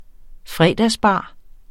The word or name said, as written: fredagsbar